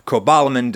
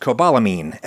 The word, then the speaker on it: cobalamin: Cobalamin does not contain an amine group, which is why it would be technically incorrect to pronounce it cobalamine, as if it has a silent E on the end.